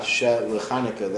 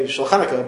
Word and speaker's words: Shel Chanukah: But not that you might think, as they point out, you might think, okay, so say it as with a Shva, Shel Chanukah, that is to Chanukah. No, we still want you to say Shulchanukah, but say it like it's one word.